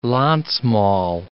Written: Landsmaal

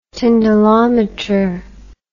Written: tyndallometer

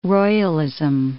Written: royalism

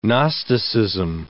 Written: gnosticism

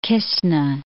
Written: Kistna

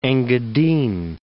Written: Engadine

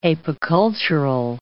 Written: apicultural

